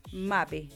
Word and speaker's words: Mbappé: Mbappé es el mejor jugador de Francia, y lindo que, sí, se pronuncia en Mbappé, ya.